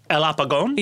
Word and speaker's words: El Apagón: El Apagón? – Yeah.